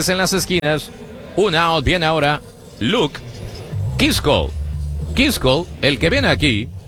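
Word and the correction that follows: Keaschall: Kitchell. En la pronunciación de los mellizos. Kitchell. Luke Kitchell.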